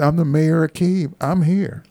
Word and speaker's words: Kyiv: We can't say Kiev anymore because that's right. the Russian pronunciation. I see the mayor of Kyiv, who's Vitaly Klitschko, former heavyweight boxing champion, about 6'7".